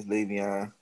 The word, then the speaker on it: Le'Veon: Okay, that's how you say it. – Yes, Le'Veon.